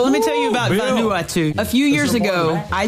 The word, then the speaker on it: Vanuatu: How do you say it? – Vanuatu.